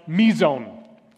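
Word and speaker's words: mizon (μείζων): This word is a word that's pronounced mizon.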